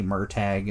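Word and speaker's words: Murtagh: Murtag or Murtal. I can't remember how to rate it. – Yeah, I don't either. – It's one way or the other. It's spelled Murtaugh, but they say Murtag in the audio book, so it's kind of like I don't know.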